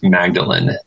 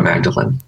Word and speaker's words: Magdalene: Okay, so my name is Scott Magdalene, like Mary, like how most people pronounce Mary in the Bible, Mary Magdalene, Scott Magdalene.